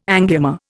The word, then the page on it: angioma: Indian